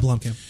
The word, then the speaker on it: Blomkamp: I think another thing that pissed me off specifically about Ridley Scott in this movie is the fact that Neil Blomkamp, or however the hell you pronounce his last name. – Yeah, Blomkamp.